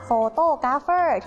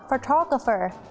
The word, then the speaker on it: photographer: เราจะไม่พูดว่า photographer ที่แปลว่าช่างภาพเราจะออกเสียงว่า photographer